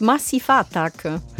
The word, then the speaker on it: Massive Attack: Nee, das heißt Massiva Attacke.